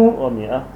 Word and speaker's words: مائة: اسمه ومئة لا الألف الألف لا تلفظ.